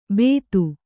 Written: ಬೇತು

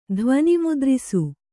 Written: ಧ್ವನಿಮುದ್ರಿಸು

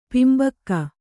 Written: ಪಿಂಬಕ್ಕ

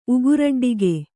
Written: ಉಗುರಡ್ಡಿಗೆ

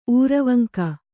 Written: ಊರವಂಕ

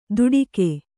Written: ತುಡಿಗೆ

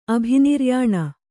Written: ಅಭಿನಿರ್ಯಾಣ

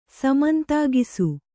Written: ಸಮಂತಾಗಿಸು

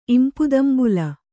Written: ಇಂಪುದಂಬುಲ

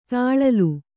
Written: ತಾಳಲು

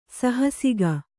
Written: ಸಾಹಸಿಕೆ

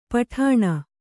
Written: ಪುಟಾಣಿ

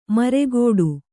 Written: ಮರೆಗೊಡು

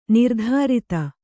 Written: ನಿರ್ದುರಿತ

